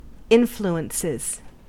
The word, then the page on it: influences: American English